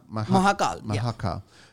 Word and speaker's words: Mahakaal: How do you pronounce it again? Sorry. Mahakaal. – Mahakaal.